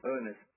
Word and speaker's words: Ernest: Ernest. – Sorry, how do you spell that? – E-R-N-E-S-T.